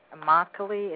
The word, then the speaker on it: Immokalee: Immokalee, is that how you pronounce it? – Correct, it is.